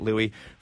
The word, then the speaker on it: Louis: That's Louis with an S, even though you pronounce it Louis.